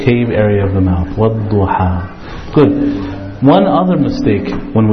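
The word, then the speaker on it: Wadduha (والضحى): So when you pronounce it, it should end in between the tongue and the roof. Wadduha.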